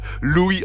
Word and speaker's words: Lughaidh: Louis Armstrong. Bah oui, enfin en irlandais, Louis s'écrit L-U-G-H-A-I-D-H. Voilà, c'est, il faut le savoir.